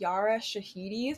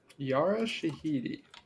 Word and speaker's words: Yara Shahidi: But yeah, I think it's Yara Shahidi is how you pronounce her name. – Yara Shahidi.